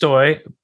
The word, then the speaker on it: Stoj: It is Stoyboy, my name pronounced Stoy, spelled S-T-O-J. And so Stoyboy, at Stoyboy, S-T-O-J-B-O-J.